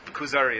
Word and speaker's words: Kuzari: But this book, Kuzari, is a fictional account, but it is his way of trying to express his ideas in terms of Jewish philosophy. – Kuzari? – Kuzari, it's called. K-U-Z-A-R-I. But the Khazar kingdom, I think it's spelled K-H-A-Z-A-R in English, so the Khazar kingdom.